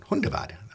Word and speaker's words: Hundevad: Hundevad.